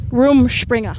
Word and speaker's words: Rumspringa: I feel like Hollywood blows up Rush Springer. Is that how you say it? – Rumspringa. – Room Springer. – There you go.